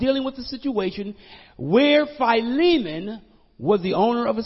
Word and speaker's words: Philemon: And in the Greek is Philemon, pronounced Philemon.